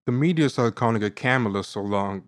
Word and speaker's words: Kamala: Is it Kamala or Kamala? What do you guys pronounce? – It's Kamala. So just picture the word comma with L-A afterwards. So Kamala.